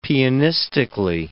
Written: pianistically

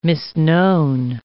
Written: misknown

